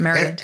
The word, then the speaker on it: Maryland: I like the way you say Maryland, because that's how it's pronounced, isn't it? Maryland.